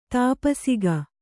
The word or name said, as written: ತಪಸಿಗ